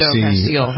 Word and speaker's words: Castile: But that fellow, is that how you pronounce his name? Castile? I think it is. – Yeah, Philando Castile.